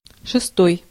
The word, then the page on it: sixth: /ˈsɪksθ/; /ˈsɪkθ/